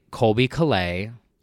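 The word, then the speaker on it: Colbie Caillat: So one of my favorite Christmas songs is an underrated gem by Colby Calais. – Wow. – Is that how you say her name? – Yeah. – Did I say it right? – Yeah.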